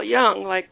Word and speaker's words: Jung: Well, Jung, like young man, right.